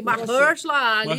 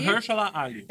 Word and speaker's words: Mahershala Ali: Mahershala Ali. Mahershala Ali.